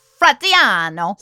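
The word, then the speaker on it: Fratianno: Jimmy, say it with an Italian accent and you'll be able to say it correctly. – All right. Jimmy the Weasel Fratiano.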